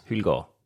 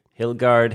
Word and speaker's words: Hyldgaard: Hyldgaard. – Hyldgaard. Mm-hmm. And how does people pronounce it? – Hyldgaard, Hildegard.